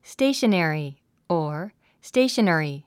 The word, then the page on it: stationery: stéiʃənèri; ステーショナリィ